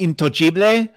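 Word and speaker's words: intoccabile: So because in Italian, it's actually pronounced intoggibile. It's untouchable.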